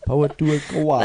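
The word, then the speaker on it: Pōhutukawa: It's the Poutukawa. Poutukawa. – That is the correct pronunciation if everyone's...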